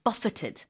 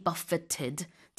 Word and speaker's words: buffeted: So with the food, it's buffet. But with this one, same spelling but different pronunciation. – And you'll see buffeted by something. – 맞아요. 그래서 buffeted. 이거는 이제 정말 영어식으로 발음을 한다고 볼 수 있겠죠. To be buffeted. T 발음 해줍니다.